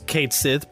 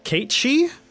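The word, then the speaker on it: Cait Sith: Yeah, it reads Kate Sith, but the official pronunciation, it's Kate Shee.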